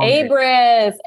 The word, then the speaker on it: Abriss: It's Erik Abriss. Did I say that right? I don't know. you were very close. It's uh, Abriss. Long. – Abriss.